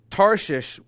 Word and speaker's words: Tarshish: Tarshish, which makes you sound stupid no matter how you say it, I am saying it correctly, I promise.